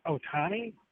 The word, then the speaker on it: Ohtani: And if they're able to land Ohtani, that's how you say it? – Yeah.